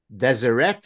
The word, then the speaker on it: Deseret: So Bethany is a columnist for Fox News and a contributing writer for Deseret. Is that how you pronounce it? – Yep.